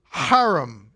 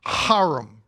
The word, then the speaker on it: Hiram: And the command of Yahweh to Hiram is how you say it. You got to get that Jewish guttural in there. Hiram, right? Sounds like you're having a bad sickness this season.